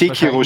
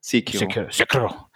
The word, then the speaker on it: Sekiro: Also wie gesagt, es wird nicht Sekiro spricht man es nur mal, dass ich das auch lerne. Sekiro.